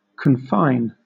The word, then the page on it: confine: British English